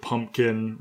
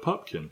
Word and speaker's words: Pupkin: Rupert Pupkin, P-U-P-K-I-N. It's often pronounced and spelled wrong, so he spells it out every time.